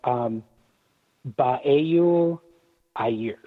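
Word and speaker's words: Beul Aithris: It's looks like Beul Athris Publishing, but it's pronounced, and I hope my publisher doesn't want to kill me when I say it, but it's pronounced Baeul Ayersh.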